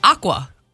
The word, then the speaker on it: aqua: I think that's your New England coming out. It's pronounced aqua.